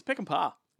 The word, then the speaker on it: Peckinpah: It's Peckinpah.